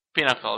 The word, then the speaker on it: Pinnacle: Hai să spunem despre live-ul la Pinnacle. – Pinnacle, da. Deci, până la urmă, asta e pronunția corectă.